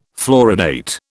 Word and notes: fluoridate: British English

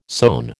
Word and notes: sone: American English

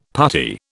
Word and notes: puttee: British English

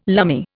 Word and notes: lumme: Indian

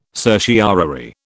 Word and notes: certiorari: British English

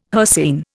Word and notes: hircine: Indian

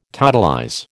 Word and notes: totalize: American English

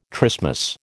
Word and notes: trismus: American English

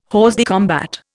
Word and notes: hors de combat: Indian

